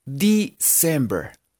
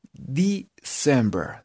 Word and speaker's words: December: Entonces, esta palabra se pronunciaría December. December. Si en la primera E suena como una I. E.